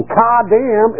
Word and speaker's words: kadam: Because the way it's pronounced, the word for prevent is pronounced ka-dam. Does that sound familiar? Ka-dam. Now, don't get me wrong. I didn't say what you said. It's K-A-D-A-M. Kadam.